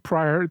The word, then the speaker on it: Pryor: Okay, my name is Keith Pryor.